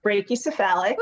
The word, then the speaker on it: Brachycephalic: I think it's brachy brachiocephalic. – Brachycephalic. Ooh, there we go.